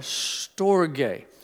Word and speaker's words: storge: The second Greek word for love is the word storge. When C.S. Lewis pronounces it, it almost sounds like he's saying stogie, not cigar, storge.